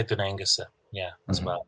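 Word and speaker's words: Dún Aonghasa: So that's how you pronounce the name of the fort on Inishmore. So Dunengus.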